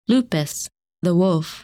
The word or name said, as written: Lupus